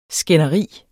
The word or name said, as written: skænderi